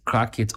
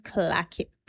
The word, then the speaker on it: Clackit: Is it Crackit or Clackit? I mean Clockit. The Americans say clack. – The right word is Clackit. – It's Clackit. – But we just make jokes out of it and say Crackit.